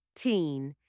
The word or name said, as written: teen